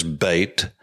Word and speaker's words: Beit: The modern Israeli pronunciation is Beit, B a I T, for instance, would be the way that you would say it and enunciate it in English.